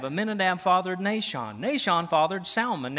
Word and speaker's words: Salmon: Now here on this one you do pronounce the S, all right? The L, excuse me. The fish, salmon, right? We don't pronounce it, right? It's not salmon patties, okay? But here that is the name, all right?